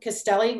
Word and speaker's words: Castelli: Castelli. My last name is spelled C-A-S-T-E-L-L-I.